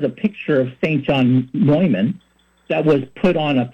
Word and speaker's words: Neumann: St. John Newman, pronounced Neumann, and St. Catherine Drexel.